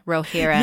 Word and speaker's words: Rohirrim: Because it talks about to the left, the river Entwash wound, and then talks about the dwellings of, Rohirrim. Thank you. Oh, that sounds so much better than whatever I just said. – Yeah. So it's Rohirrim. Like Rohan, Rohirrim.